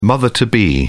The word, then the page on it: mother to be: British English